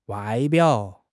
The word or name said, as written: 外表